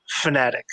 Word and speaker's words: Fanatec: and he said technically it's Fnatic because you know these people are racing fanatics so that's how it's supposed to be pronounced but he also said it doesn't really matter so to go, to go with.